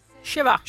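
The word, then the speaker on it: schwa: In un articolo, comunque, è riuscita a far pubblicare l'articolo che ha scritto con questa Shewa. Abbiamo detto giusto? – Shewa.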